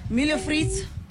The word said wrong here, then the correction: Milho frito: Am I saying it right? – Close. Yeah, sure. – How do I say it? – Milho frito. – Milho frito.